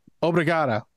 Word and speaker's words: Obrigada: Obrigada. That's as much as I remembered. – You did well. You said it right.